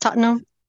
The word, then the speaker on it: Tottenham: Tottenham is how it's, well, this is how I was told to pronounce it, Tottenham.